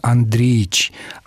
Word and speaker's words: Андреевич: По поводу отчеств, единственно правильный способ произнесения – Андреич. Андреевич – так нужно писать, а произносит отчество с обязательным выпадением слога.